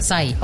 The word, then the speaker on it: Psy: Que como se escribe P-S-Y. Entonces, Sai. – Sai. Ok, gracias.